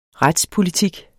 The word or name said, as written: retspolitik